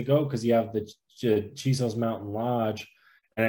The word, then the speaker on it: Chisos: Chisos Mountains. – Oh yeah, Chisos, you know. – Chisos. Oh yeah, that's probably the correct Chisos Mountains.